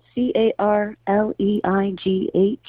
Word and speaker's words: Carleigh: It's all, uh, my name is spelled C A R L E I G H. So it's a tricky spelling of Carly.